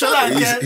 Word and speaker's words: Solanke: I've never heard anyone say it like that. – Yeah, it's Solanke. – He's Nigerian.